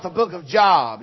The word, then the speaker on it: Job: I know that it's pronounced Job and not Job because 6% of Americans wouldn't be able to find it.